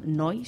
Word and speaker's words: Neu: El sonido de bandas alemanas de la escena krautrock como Can, Kraftwerk y Neu, que he aprendido a decir cómo se dice Neu en alemán, Neu,